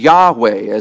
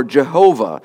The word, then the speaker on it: Yahweh: In the Hebrew here, we see the tetragrammaton, the YHWH, Yahweh, as it's often pronounced, or Jehovah, the name of God.